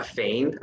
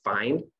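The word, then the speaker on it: Fein: I had a Fane, I think that's how you say it, or Fine.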